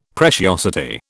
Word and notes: preciosity: British English

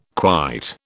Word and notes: quite: British English

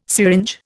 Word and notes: syringe: Indian

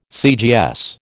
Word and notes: CGS: American English

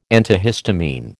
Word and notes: antihistamine: American English

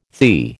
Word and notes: THI: American English

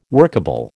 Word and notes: workable: American English

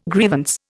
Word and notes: grievance: Indian

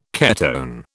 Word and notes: ketone: British English

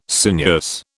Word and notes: sinuous: British English

